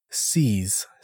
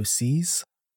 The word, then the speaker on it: Seiz: By the way, my name is Seez. Spells it S-E-I-Z, but it sounds like S-E-E-S, so Seez.